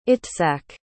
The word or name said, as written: itzak